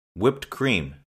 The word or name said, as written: whipped cream